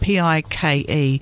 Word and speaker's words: Pyke: His parents called it P I K E, but um, they changed it to P Y K E, but still pronounced Pike.